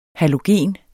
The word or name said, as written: halogen